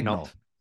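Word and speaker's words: Daigneault: Is Mark Degnault? Is that how you pronounce it? Degnault. – Yeah. Degnault. There we go.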